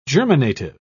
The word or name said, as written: germinative